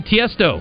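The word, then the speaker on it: Tiësto: And speaking of music, Tiesto.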